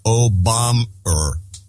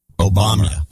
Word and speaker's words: Obama: On the last show, there was a subtle mispronunciation of Obama, which sounded like Obam-er. Obama.